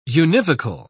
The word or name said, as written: univocal